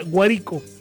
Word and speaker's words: Guárico: Y allá en Lara está, se escribe Guárico, pero se pronuncia Guárico, Guárico. Y en Guárico está la,